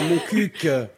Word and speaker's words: Moncuq: Vous habitez à Moncu. – Oui. – Pardon. – Non, Moncuq. – On prononce Moncu ou Moncuq ? – Non, Moncuq. – Ah, Moncuq. – Voilà.